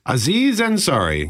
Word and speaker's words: Aziz Ansari: Aziz Ansari.